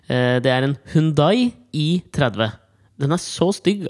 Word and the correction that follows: Hyundai: Ja, Det uttaler vel Hyundai. Hvordan er det det uttales? Hyundai. – Hyundai.